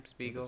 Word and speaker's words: Spiegel: Last name Spiegel. – That's how you pronounce it.